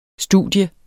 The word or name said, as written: studie